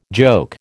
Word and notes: joke: American English

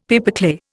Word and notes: pipeclay: Indian

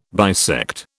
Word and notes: bisect: British English

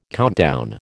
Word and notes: countdown: American English

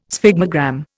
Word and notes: sphygmogram: Indian

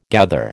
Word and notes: gather: American English

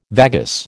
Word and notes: vagus: American English

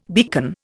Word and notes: beacon: Indian

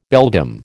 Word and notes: beldam: American English

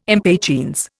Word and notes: impatiens: Indian

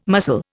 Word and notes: muscle: Indian

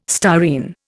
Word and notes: styrene: Indian

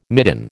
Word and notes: midden: American English

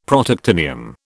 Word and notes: Protactinium: British English

